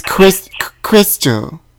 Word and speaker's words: Crystal: It's Crystal. – Can you spell that, please? – C as in cat, R-Y-S-T-A-L, Crystal.